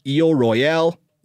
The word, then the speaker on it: Isle Royale: If you want to make it French, you call it Isle Royale. If you want to make it English, you call it Isle Royal. The incorrect way, which is often how I do it, is pronouncing it Isle Royale, because that's making the first word English and the second word French.